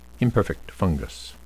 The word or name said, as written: imperfect fungus